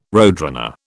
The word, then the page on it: roadrunner: British English